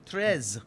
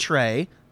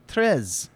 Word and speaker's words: treize: Treize. – Not Trey. Trez.